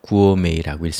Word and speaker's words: gourmet: 읽을 때 조심해야 되는데요. Gourmet라고 읽습니다. T가 프랑스에서 왔기 때문에 묵음이 되고 읽혀지지 않습니다. Gourmet라고 하는데요.